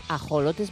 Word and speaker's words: ajolotes: No sé por qué la X en México se pronuncia como J, o sea que serán ajolotes mexicanos.